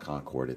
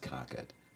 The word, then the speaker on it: Concord: It's not Concord, it's pronounced Concord.